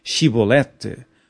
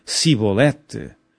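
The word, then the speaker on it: Chibolete: E dizendo ele: Não, então lhe diziam: Dize, pois, Shibolete. Porém ele dizia: Sibolete, porque o não podia pronunciar assim bem.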